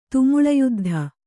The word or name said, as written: ತುಮುಳಯುದ್ಧ